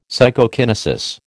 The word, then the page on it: Psychokinesis: American English